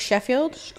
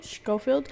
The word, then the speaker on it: Schofield: And Brooke, I don't want to mispronounce her last name, but Brooke Sheffield. – Schofield.